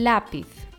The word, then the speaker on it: lápiz: el lápiz. Hier wird es mit Betonungszeichen die Wörter Indianer also in der vorletzten Silbe betont werden und auf Konsonanten enden, außer N oder S, dann wird ein Betonungszeichen benutzt. Drittens, Estruchula.